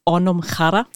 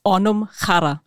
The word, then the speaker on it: Anamkara: Richtig, ja, also ganz korrekt ausgesprochen ist es Onumkhara. – Ah, oh, sorry. Irish listeners, aber okay. Ist das normal? – Onumkhara.